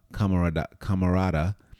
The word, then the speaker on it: camarada: Matter of fact, the Spanish meaning of the word comrade, camaradera, I'm saying it wrong, camarada, which it means chambermate.